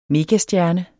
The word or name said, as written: megastjerne